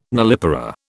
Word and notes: nullipara: British English

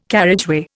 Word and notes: carriageway: Indian

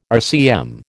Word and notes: RCM: American English

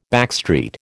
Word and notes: backstreet: American English

